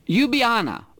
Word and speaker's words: Ljubljana: I went to a rail museum in Ljubljana. That's how they say it. It starts with an L, but it's how pronounced Ljubljana in look at that.